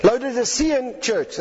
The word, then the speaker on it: Laodicean: Laodicean church.